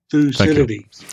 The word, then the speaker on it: Thucydides: So there's no single pronunciation, but in English, we call it Thucydides trap. – Thucydides.